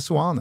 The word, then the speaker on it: Seoane: S-E-O-A-N-E. Det är svårt att hitta något annat än ett fonetiskt Seoane.